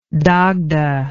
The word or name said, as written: dagda